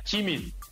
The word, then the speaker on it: Jimin: el nombre es Jimin. Jimin. Se pronuncia Jimin.